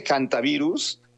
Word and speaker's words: hantavirus: Se dice hantavirus, aunque se escribe con H, por la cuestión anglosajona que hacen que la H suene como J. Pero es hantavirus.